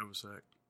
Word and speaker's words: Novoselic: Is that how you say it? – Yeah. Novoselic. – Novoselic? – Yeah. – There's an L in it. – It's silent.